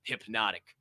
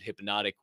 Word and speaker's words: Hpnotiq: I too was also today years old when I learned hypnotic was spelled with no Y. It is H P N O T I Q.